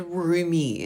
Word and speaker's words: rheumy: She also hated the word roomy. Have you ever heard that? Not as in like, oh, I'm wearing a roomy pair of pants. No. It's spelled R H E U M Y and it's just it's like watery eyes, or it's usually described.